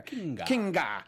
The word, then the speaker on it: Kinga: Felicia Day's character is Kinga, or just Kinga Forrester. I think I had a little too hard G there. – Kinga.